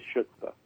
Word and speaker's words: chutzpah: a lot of chutzpah, or I can't remember how Mich- Michele Bachmann pronounced it, but I believe it was chutzpah.